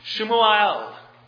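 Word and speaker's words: Shmuel (שמואל): The word Samuel is in the Hebrew pronounced Shmuel. You can hear something of the assonance. Three of the main consonants are present in both of those words, sha'al, Shmuel.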